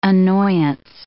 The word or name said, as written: annoyance